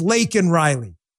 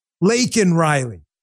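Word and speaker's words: Laken Riley: That Lincoln Riley, her name is Lakin Riley. Lakin Riley. She deserves respect. You say her name, you say it right.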